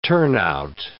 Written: turnout

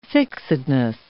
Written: fixedness

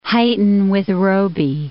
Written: Huyton with Roby

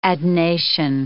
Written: adnation

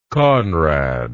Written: Konrad

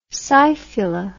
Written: scyphula

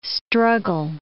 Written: struggle

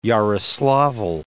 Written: Yaroslavl'

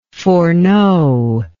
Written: foreknowingly

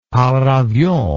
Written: paravion

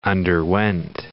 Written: underwent

